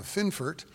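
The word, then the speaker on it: Fionnphort: Finfort. It looks like it's spelled Fionfort, but it's pronounced Finfort. And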